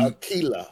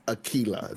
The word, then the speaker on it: Aquila: When you sit there and say, this is off of a Greek goddess, then it is an Aquila. – Yeah. – Aquila. Okay. – Yep. – And it clearly tells you how to break it down. And it is K-E-Y. It is not Q-E, it's Aquila. Yep. So that's the correct pronunciation.